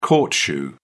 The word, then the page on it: court shoe: British English